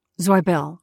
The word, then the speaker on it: Zweibel: is it Alan Zweibel or Zweibel? – Zweibel. – Zweibel. I should have stuck with my first instinct. Just want to make it clear. I know who he is. I just was doubting the pronunciation.